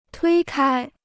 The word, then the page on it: 推开: tuī kāi